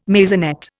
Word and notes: maisonette: Indian

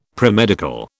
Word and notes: premedical: British English

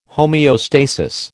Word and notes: homeostasis: American English

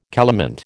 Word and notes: calamint: American English

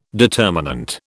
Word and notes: determinant: British English

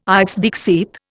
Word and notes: ipse dixit: Indian

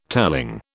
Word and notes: telling: British English

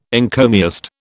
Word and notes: encomiast: British English

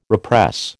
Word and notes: repress: American English

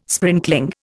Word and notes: sprinkling: Indian

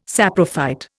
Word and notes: saprophyte: Indian

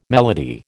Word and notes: melody: American English

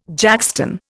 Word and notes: jackstone: Indian

